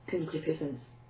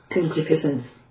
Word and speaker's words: concupiscence: Concupiscence. – What? Listen. – Concupiscence. – Let's see how they say it in Britain. – Concupiscence.